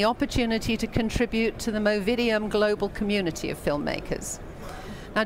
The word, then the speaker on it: Movidiam: Have I said it correctly? – Movidiam, yeah.